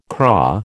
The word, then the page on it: craw: American English